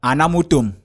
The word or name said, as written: ANAMUTOM